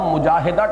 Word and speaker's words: مجاہدہ: اصل میں ہم مجاہدہ کہہ دیتے ہیں، عربی میں اس کا تلفظ مجاہدہ ہوگا، اس لیے کہ یہ باب ہے مفاعلہ،